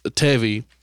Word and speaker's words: Devi: No, so my raptor's name is Tevi, and... – Like Chevy, but... – No. It's spelled D-E-V-I. It's a Hindi name.